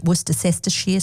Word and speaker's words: Worcestershire: Bit of a Worcestershire sauce, or as I've recently heard it pronounced, Worcestershire sauce.